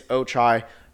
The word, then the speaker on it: Ochai: He did clarify to me that that is how you say his name. Luckily, actually, not to me directly, but I was in the room and someone else asked him after calling him Ochai. It is Ochai.